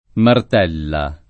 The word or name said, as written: Martella